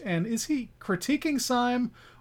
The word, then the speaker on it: Syme: I want to start by talking about the protagonist of this novel, Gabriel Syme. Syme. I don't know how you guys pronounced it in your head. I say Syme. – Simi. Simi Gabriel.